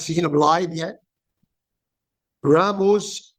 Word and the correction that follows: Rasmus: Raiola. I hope I said that correctly. 31 years old. – It's Rasmus, but almost.